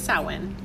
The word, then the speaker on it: Samhain: It's Samhain.